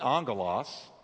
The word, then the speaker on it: angelos: The word angel comes from the Greek word angelos. That's how you say it, angelos.